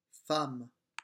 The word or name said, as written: femme